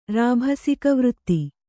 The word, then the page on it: ರಾಭಸಿಕವೃತ್ತಿ: Kannada